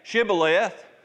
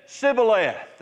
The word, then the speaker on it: Shibboleth: couldn't say Shibboleth. They said Sibboleth. They couldn't frame to say it correctly.